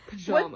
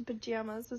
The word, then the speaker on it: pajamas: What divides the country when it comes to pajamas is the word second syllable. Head to America's western and midwestern states and you find that the A in pajamas is pronounced like jam. But spend time in any southern or eastern, you'll hear the A as in father.